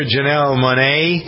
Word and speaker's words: Janelle Monáe: Octavia Spencer, Janelle Monáe, is that how you pronounce her name? – Yep.